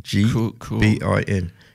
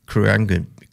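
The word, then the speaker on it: Khruangbin: A band called Krangbin. – Sorry? – Krangbin. – How do you spell that? – Krungbin. – Krungbin. – Krungbin. – Krungbin. Yeah, don't try and say all the letters.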